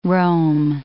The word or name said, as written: Rom